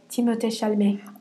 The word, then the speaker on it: Timothée Chalamet: Let me say it, like, how it's actually pronounced. Okay. Timothée Chalamet.